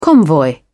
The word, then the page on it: convoy: British English